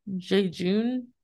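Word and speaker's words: Jejune: Jejun Institute. I don't know if I'm pronouncing that right. It's J-E-J-U-N-E. Jejun Institute?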